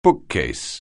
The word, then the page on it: bookcase: anglais